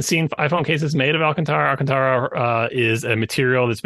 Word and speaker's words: Alcantara: I always pronounced it Alicantara, which is not even close to correct, but nope. It's Alcantara or close to that anyway.